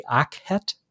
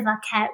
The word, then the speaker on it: akhet: Am I pronouncing this correctly? Akhet? – I think that's right. You know, we're not actually sure how it's pronounced because the vowels have been lost to history. It's just the hieroglyph from which we've reconstructed the word, just with its consonants.